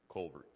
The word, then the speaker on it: Calvert: It's like Calvert County? – No, Colvert County. – But that's an A. – Yeah, but it's pronounced U, Colvert.